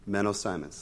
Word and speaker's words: Menno Simons: I'm going to mispronounce our father of our church, Milo. Menno Simons, thank you.